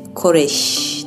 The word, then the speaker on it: Koresh: David Koresh vurulduğu zaman kendisini arayan FBI ajanı soyadın nasıl okunuyor diye sormuştu ve Koresh demişti. Yani ölüm sessizliği gibi şşş demişti.